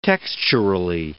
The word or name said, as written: texturally